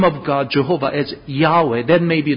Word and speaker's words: Jehovah: So instead, even though they are using the same consonant, but they are putting a different vowel pointings, as a result, the pronunciation becomes Jehovah.